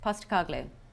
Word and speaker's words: Postecoglou: Yeah, Ange Postecoglou. – Postecoglou. Say it as quick as you can. – Postecoglou.